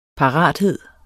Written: parathed

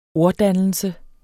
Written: orddannelse

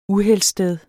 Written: uheldssted

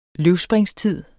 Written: løvspringstid

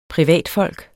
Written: privatfolk

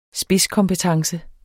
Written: spidskompetence

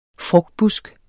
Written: frugtbusk

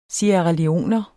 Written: sierraleoner

